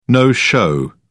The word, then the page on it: no-show: British English